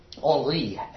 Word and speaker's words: Henri: Henri and Marguerite in the French pronunciations.